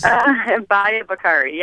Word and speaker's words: Bahia Bakari: One we didn't talk about yet, and forgive me in this pronunciation, Bahia Bakari. Did I come anywhere close? – Bahia Bakari. Yeah, Thank you.